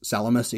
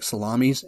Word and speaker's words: Salamis: And it is pronounced Salamis, even though it looks like Salamis.